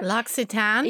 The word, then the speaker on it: L'Occitane: L'Occitane? – Yes.